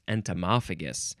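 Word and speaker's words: entomophagous: Entomorphagus? Entomophagus. Let's see. The word's right here. I can look at the actual pronunciation. Entomophagus. That's it. Entomophagus. Something about insects. Next is, this is an abbreviation, entom or entomol, E-N-T-O-M, or you can put in an O-L at the end, and it means entomological or entomology.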